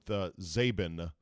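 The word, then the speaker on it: Czaban: I bet my ancestors chopped off the S-K-I. And somewhere along the line, my family said, well, we're going to go with Zabin with a silent C on the front.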